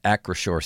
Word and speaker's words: Acrisure: August 29th through September 2nd at Acrisure Stadium. Huh. There's a little pronunciation here that says Acrisure. I think I've been calling it Acrisure.